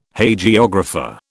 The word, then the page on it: Hagiographer: British English